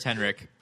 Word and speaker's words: Henrik: I'm pretty sure it's Henrik. – Okay. – One of my favorite hockey players is Henrik Zetterberg of the Detroit Red Wings, and he's from Sweden, and it's pronounced that way.